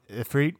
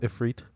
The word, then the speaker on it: Ifrit: is it pronounced Ifrit? – I believe so.